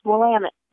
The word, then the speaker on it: Willamette: One of the ways that the tour companies have you remember that is they say it's Willamette, damn it.